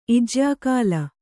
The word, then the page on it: ಇಜ್ಯಾಕಾಲ: kannada